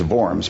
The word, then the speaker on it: Worms: So ultimately, they decide that the hearing is going to take place in Worms. Now, in German, the W is actually pronounced as a V, so it's Worms, but the average American doesn't know that.